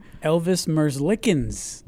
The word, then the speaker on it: Elvis Merzlikens: Elvis Merzlikens.